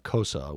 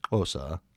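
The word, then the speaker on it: Xhosa: One of the professors who was Kosa, which they pronounce Kosa, it's clicking and I do a horrible job of it, but he brought in a goat's head and they had grilled the goat's tongue and goat eyeballs.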